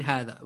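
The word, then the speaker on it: hadha (هذا): That is indeed hadha.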